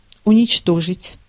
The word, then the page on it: annihilate: /əˈnaɪə.leɪt/